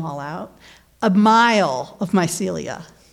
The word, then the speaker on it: mycelia: But you know, these mycelia, if I'm pronouncing that right, am I? – You got it.